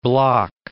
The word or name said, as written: Bloch